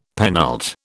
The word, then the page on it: penult: British English